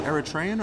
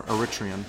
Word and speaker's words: Eritrean: And I was surprised to learn yesterday that he's actually of is it Eritrean or Eritrean? Uh, you can say both. I mean, it's just more of a pronunciation thing.